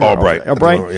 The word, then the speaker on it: Albrecht: But I mean, but what you're saying is true because when Chris, what is Chris Albrecht? Is that how you say his name? – Albright.